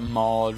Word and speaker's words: Maud: Turns out Maud rhymes with God.